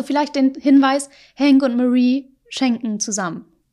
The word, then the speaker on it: Marie: Hank wird zu Hause zu Marie sagen, Hank, Marie und Walter, die Namen werden englisch ausgesprochen. Es ist Marie und Walter.